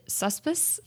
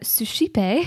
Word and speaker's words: suscipe: I thought that it was pronounced, well, I didn't actually know how it was pronounced, but in my mind, I pronounced it suspice. But it is actually suscipe. It is Latin, the Latin word for receive, which...